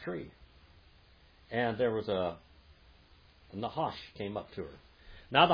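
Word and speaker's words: Nahash (נחש): Say Nahash. – Nahash. – If you got to clear your throat. Say that, Brother Roger. Say it right. – Nahash. – Nahash. – Nahash. – Like clearing your throat. Nahash.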